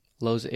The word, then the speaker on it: Lausanne: I don't know if that just got edited, but how do you say it? Lausanne? – Lausanne. Yeah, Lausanne.